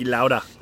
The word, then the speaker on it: Niki Lauda: Niki Lauda.